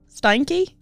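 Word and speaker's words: Steinke: Are you are you certain you're pronouncing his last name right? Just because that's that's so unfortunate. I know. I, mean, I it's S T E I N K E. Stanky.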